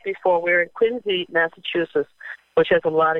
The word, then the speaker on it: Quincy: That's here, and they pronounce it Quincy, Massachusetts, although it says Quincy.